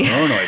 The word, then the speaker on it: Voronoi: It was a Voronoi, if I'm pronouncing that correctly. – Voronoi chart. Yeah. These are tricky.